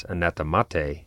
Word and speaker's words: anathemate: By the way, in ecclesiastical Latin, the th consonant group is pronounced simply as a t. For example, it's theologia rather than theologia. That's why I pronounced the ablative form of the Latin word anathema as anathemate, in case anyone was wondering.